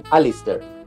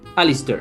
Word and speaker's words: Alasdair: En realidad, depende si lo pronuncia un americano, un británico o un escocés, pero dejémoslo en Alistair. – Alistair.